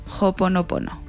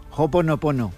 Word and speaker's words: Ho'oponopono: Quédate con esta palabra, Ho'oponopono. – Ho'oponopono. – Ho'oponopono. – Ho'oponopono.